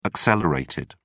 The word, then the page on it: accelerated: British English